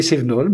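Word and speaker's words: Cévaenols: Et ça se prononce exactement comme Cévenol, hein, on est d'accord. Il ne faut pas aller dire C-V-A-E-N-O-L parce que c'est imprononçable. Non, c'est des Cévenol, mais avec un clin d'œil au vélo électrique.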